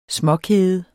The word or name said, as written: småkede